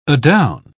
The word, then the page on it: adown: British English